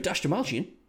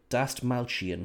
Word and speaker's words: Dastmalchian: David Dastmalchian. Wait, Dastmalchian. I have to try and pronounce that rightly, because we're going to be pronouncing it when we're doing the Suicide Squad. – Yes, Dusted Malchian.